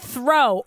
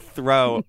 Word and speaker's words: Thoreau: This book I read on the Cape is called Walden Pond by this guy, Henry David Thoreau, or as you call him, Thoreau. – Okay. I don't know where you, Lily Murata, like, garnered your pronunciation of Thoreau. But you've said throw every time.